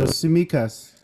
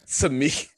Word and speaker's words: Tsimikas: The T is silent. So Tsimikas. – Tsimikas.